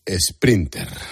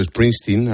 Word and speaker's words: Springsteen: Bruce Sprinter. Así llamó Núñez Feijóo a Bruce Springsteen al hacer una metáfora con el viaje de Sánchez a Galicia.